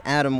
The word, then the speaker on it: Adam: My name is Adam White, A-D-A-M, White, W-H-I-T-E.